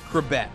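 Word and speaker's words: Chrebet: His last name, pronounced Krabet, became a household name after he made the 53-man roster out of training camp in 1995.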